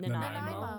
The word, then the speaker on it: Nanaimo: Is it Nanaimo? I can never say it right. – Nanaimo. – Where the bars come from. – Yeah, yeah. That's exactly good. – Right. – There we go.